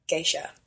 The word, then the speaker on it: geisha: And um, I just caught myself, Molly, saying geishas, but in fact, geisha is both singular and plural, so I should have said geisha. So here on out, geisha. Geisha.